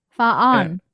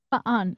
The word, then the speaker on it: fan: F A N. Faan. Faan? – Yeah. – Faan. Okay, I'm going to remember that.